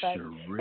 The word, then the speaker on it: Sherree: It's Sherree. – So it's Sherree.